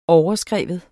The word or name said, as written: overskrive